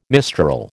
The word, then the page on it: mistral: American English